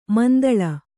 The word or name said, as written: ಮಂತುಳ್ಳಿ